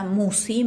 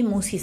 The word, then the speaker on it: Musi: El canal lleva mi nombre, Marisa Musi. Musi se escribe MUSC y latina.